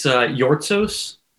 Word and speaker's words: Yortsos: It's Yortsos, Greek, but yeah, Chris Yortsos is the name.